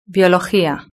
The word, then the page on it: biologia: Spain Spanish